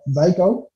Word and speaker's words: Xiko: The name of of his company was, well, it's XIKO. I'm believing this is pronounced Zyko.